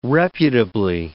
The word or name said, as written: reputably